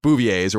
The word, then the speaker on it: Bouvier: They're Bouviers or whatever. That's how you say her name, I think. Right? – That was yeah, um. That's right.